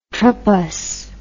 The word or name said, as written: tropous